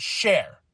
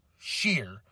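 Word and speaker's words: Sher: Indus Sheer. Not Share. Sheer.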